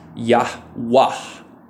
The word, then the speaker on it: Yahweh: Interestingly, the Arabic tradition, the Arabic translation that's most used, the pronunciation is Yahwah. Y-A-H-W-A-H, and it's a heavy H, or it's a hard H at the end, so Yahwah.